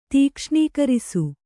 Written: ತೀಕ್ಷ್ಣೀಕರಿಸು

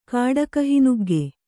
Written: ಕಾಡಕಹಿನುಗ್ಗೆ

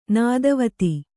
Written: ನೆತ್ತಿವತ್ತು

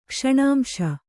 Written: ಕ್ಷಣಾಂಶ

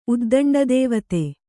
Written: ಉದ್ದಂಡದೇವತೆ

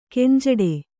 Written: ಗುಂಜಾಡು

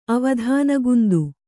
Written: ಅವಧಾನಗುಂದು

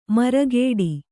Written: ಮರಗೇಡಿ

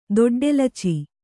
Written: ದೊಡ್ಡೆಲಚಿ

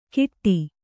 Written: ಕಿಟ್ಟಿ

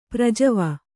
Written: ಪ್ರಜವ

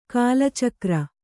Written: ಕಾಲಚಕ್ರ

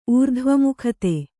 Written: ಊರ್ಧ್ವಮುಖತೆ

